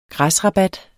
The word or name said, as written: græsrabat